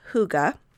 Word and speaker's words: hygge: It's actually spelled H-Y-G-G-E. It doesn't look at all like hygge.